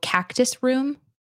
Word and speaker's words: Cactus Room: It's called Cactus Room, which rhymes with practice room.